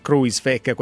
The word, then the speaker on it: Kruijswijk: Come si pronuncia? – Kruijswijk. – Quindi ecco, così. Quindi siccome tu lo pronunci Kruijswijk, per noi sarà così.